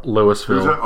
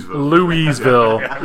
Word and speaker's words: Louisville: Or Louisville. – Louisville. – Sorry, I pronounced it wrong. – Quite right. Louisville.